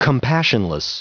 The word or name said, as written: compassionless